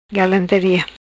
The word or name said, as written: Galantaria